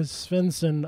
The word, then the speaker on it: Svensson: and Peter Svensson of The Cardigans. – Perfect. – There you go. – That's how perfect pronunciation of Svensson.